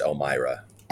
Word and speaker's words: Elmira: Yeah, it's about a four and a half hour drive north northwest, pronounced Elmira.